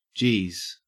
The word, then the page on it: geez: Australian English